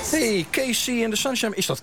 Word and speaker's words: KC: Casey is met K-E-E-S-S-I-E. – Haal ik altijd door elkaar. – En dit is KC. Maar het is Engels. Dus dan spreek je het uit als Casey.